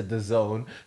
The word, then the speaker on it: DAZN: Is it DAZN? – Yeah, DAZN.